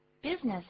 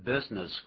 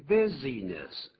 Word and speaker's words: business: Business has been good recently. – 你看 business 果然前面没有加冠词，但念这个字不要念成 business。 – 对，要念 business 两个音节而已，business。